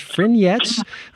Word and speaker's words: Fringettes: has gone online with something that looks like Fringettes, but I guess it's pronounced Frignettes, I'm told, by Jade Hales, who's the music producer for the Oregon Fringe Festival.